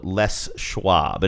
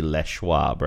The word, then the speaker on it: Les Schwab: This is for a company, a local tire company called Les Schwab, and it is pronounced that way, although it looks like it should be Les Schwab, right? L-E-S? – Yeah.